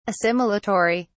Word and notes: assimilatory: British English

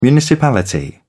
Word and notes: municipality: British English

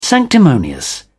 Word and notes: sanctimonious: British English

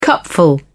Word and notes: cupful: British English

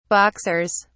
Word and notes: boxers: British English

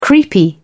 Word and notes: creepy: British English

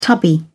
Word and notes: tubby: British English

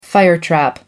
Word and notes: firetrap: American English

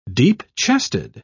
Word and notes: deep-chested: British English

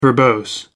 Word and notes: verbose: American English

